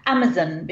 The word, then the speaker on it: Amazon: You can get it on Amazon, because that's how you say it over there. Amazon, not Amazon.